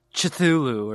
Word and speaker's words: Cthulhu: Yeah, the old one himself could awaken as from his sleep in Ryla, Cthulhu. – Oh, okay. I mean, is that how you pronounce it? – That's how I've always said it. – Okay, you say like Cthulhu or something.